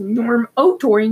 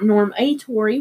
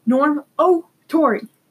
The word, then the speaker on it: Normotory: Norm O'Tory, not Norm A. Tory, like most people do. Norm O'Tory. – N-O-R-M-O-T-O-R-Y.